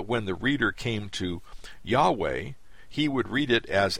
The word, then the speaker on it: Yahweh: In English, these four Hebrew letters, YHWH, called the Tetragrammaton, were formerly rendered Jehovah, King James Version, but are better pronounced Yahweh.